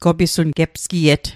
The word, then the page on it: gepsgiet: Mi'gmaq